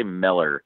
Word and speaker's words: Mueller: Oh yeah, well uh, John Miller, um, it's spelled Mueller, but they they say Miller.